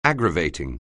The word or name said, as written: aggravating